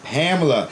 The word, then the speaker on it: Pamela: And my real fiance rhymes with Kamala. – Ah. – It's Pamela.